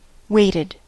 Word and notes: waited: American English

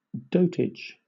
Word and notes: dotage: British English

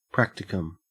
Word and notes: practicum: Australian English

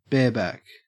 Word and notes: bareback: Australian English

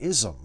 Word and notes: ism: American English

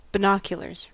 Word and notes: binoculars: American English

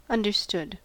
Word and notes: understood: American English

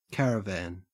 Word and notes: caravan: Australian English

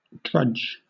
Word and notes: drudge: British English

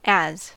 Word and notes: as: American English

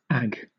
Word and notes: ag: British English

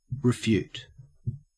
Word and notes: refute: Australian English

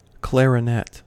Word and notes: clarinet: American English

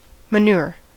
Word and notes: manure: American English

